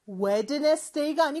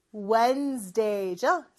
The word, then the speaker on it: Wednesday: 수요일은 Wednesday가 아니고 Wednesday죠. 그래서 D 발음은 생략된다 라고 보시면 돼요.